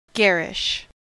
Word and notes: garish: American English

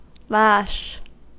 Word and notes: lash: American English

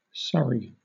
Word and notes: surrey: British English

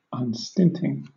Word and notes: unstinting: British English

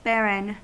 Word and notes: barren: American English

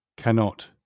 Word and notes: cannot: British English

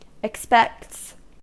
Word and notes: expects: American English

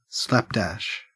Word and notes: slapdash: Australian English

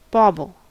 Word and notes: bauble: American English

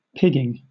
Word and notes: pigging: British English

